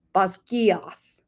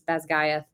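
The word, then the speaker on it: Basgiath: So she said it should have been Bazgiath. – Bazgiath. – Bazgiath. And I'm sorry if I'm butchering that again. I tried to write it exactly the way she wrote it. Bazgiath. And so if I'm actually probably going to put her link in or her TikTok video in this so you could go back. But we are going to use the way Rebecca Yarros pronounces it, which is Bazgaith.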